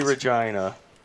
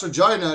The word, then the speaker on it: Regina: It has to be Regina. – It's pronounced Regina, and if you don't like that, what is it?